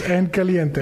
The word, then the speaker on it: en caliente: So, again, is going to be in caliente. Did I say that right? – Yes, en caliente.